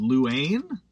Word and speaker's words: Louane: i believe this is pronounced Louane uh, l-o-u-a-n-e uh,